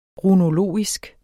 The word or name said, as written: runologisk